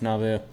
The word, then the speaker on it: Nauvoo: One thing that I saw is they'd moved around quite a bit, and when they got to Nauvoo, Illinois. – Nauvoo. – Yeah. Is that how it's pronounced? – Yeah, it's Nauvoo.